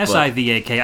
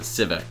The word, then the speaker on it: Sivak: This track is Area 1 and it's by either Sivak, CIVAC. We really couldn't figure it out. – S I V A K. I've always pronounced it Sivak. Right. I always called it Civic. So Like the car.